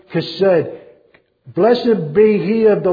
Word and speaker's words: chesed: C-H-E-S-E-D. If you want to know how to say the k in the beginning, the chesed, remember the word bach. And chesed is said the same way.